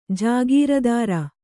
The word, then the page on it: ಜಾಗೀರ್ದಾರ್: jāgīrudār